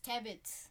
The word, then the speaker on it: Tibbetts: And Chris Tibbetts. I don't know how to say it. Chris Tibbetts.